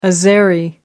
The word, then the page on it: azeri: American English